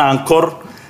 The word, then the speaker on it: Anchor: Sí, esta aplicación se dice Anchor, por si no lo pueden encontrar en Anchor, eh, se escribe así. Sí, es que eh, se pronuncia Anchor en inglés eh,